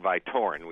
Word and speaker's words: Vytorin: Well, it's a complicated study, but in essence, what they did is looked at the drug Vytorin, which is how it's usually pronounced,